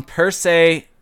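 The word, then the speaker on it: Percé: Thoughts on Perce Quebec. – Perce, how do you spell that? – Perce Quebec. It has you actually pronounced that correctly.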